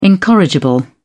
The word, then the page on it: incorrigible: British English